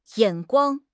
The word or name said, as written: 眼光